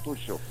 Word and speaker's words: Tuchel: Tuchel. É, a pronúncia aí, eu acho que é Tuchel mesmo.